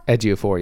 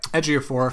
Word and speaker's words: Ejiofor: You've got, I never get his name right, Chiwetel Ejiofor? – Ejiofor, yeah. – Ejiofor.